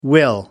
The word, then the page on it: will: American English